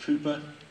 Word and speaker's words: Cowper: And it reminds me of an old hymn from a guy called William Cowper, spelled Cowper, pronounced Cooper, an old English guy who knew a lot about hard times and trials.